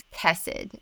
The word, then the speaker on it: Khesed: And so the way that we did it in terms of naming our child is we put a K in front of the H. So we spell it K-H-E-S-E-D, which is chesed. And I think that's probably easier for an English-speaking culture to say.